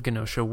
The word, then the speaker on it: Genosha: You got Professor Xavier sending the X Men to an island called what is it, Genosha? I'm saying that correctly. – He I sends. I pronounced it. Okay, so he sends him to the island of Genosha, where there's some shady stuff going on.